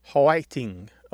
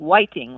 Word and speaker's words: hwaiting: It's often spelled H-W-A-I-T-I-N-G or P-A-I-T-I-N-G, and it sounds like three syllables instead of two, so it might be like hoiting or piting, like that. – Yes. And sometimes it sounds like whiting, like with a W almost.